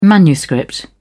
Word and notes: manuscript: British English

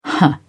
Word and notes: huh: British English

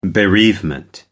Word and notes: bereavement: American English

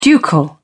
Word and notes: ducal: British English

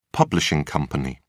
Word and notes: publishing company: British English

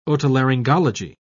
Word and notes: otolaryngology: British English

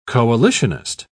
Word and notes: coalitionist: British English

